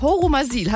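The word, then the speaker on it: Horumersiel: Im schönen Örtchen Horumasil. Habe ich das richtig ausgesprochen, Christian? – Ja, das habt ihr korrekt.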